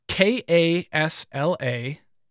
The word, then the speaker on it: Kasla: So Kasla, the Broken Halo. – How do you spell it? T A K A S L A.